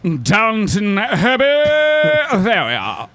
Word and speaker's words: Downton Abbey: Everyone calls it Downtown Abbey.